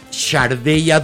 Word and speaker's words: Sade: Y eh, su nombre es Shardey así es como se pronuncia. Se escribe Sade, como el marqués.